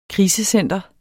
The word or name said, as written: krisecenter